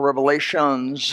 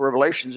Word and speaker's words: Revelation: The book of Revelation is not the book of Revelations. A lot of people mispronounce it. Look at the book of Revelations. It's Revelation.